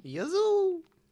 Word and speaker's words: Yazoo: Yazoo, that's how it's pronounced.